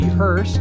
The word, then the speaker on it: Hurst: Hurst, and in case you don't know, the Hurst in that name is spelled H-U-R-S-T, or you can follow the official Twitter account for the podcast, at HMME_podcast.